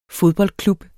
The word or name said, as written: fodboldklub